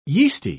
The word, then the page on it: yeasty: English; British English